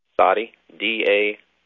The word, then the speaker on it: Soddy Daisy: Soddy Daisy, Tennessee. – Soddy Daisy? – How do you spell that? S-A? – No, S-O-D-D-Y. – Soddy. – Soddy. D-A-I-S-Y. – Soddy Daisy.